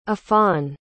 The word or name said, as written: affan